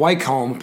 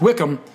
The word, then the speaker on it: Wycombe: I called them Wycombe because it's spelled W Y O W Y C O M B E. Could be Wycombe, but it's Wickham.